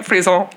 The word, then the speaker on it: frisson: Did I say it right? Frisson. – You did, listener, she did what I would say is a francophiliac little twist of the wrist.